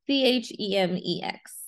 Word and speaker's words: Chemex: And then Chamex pour over, I think that's how it would be pronounced. It's Chemex. So we'll find out what that is.